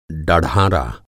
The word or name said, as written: डढ़ारा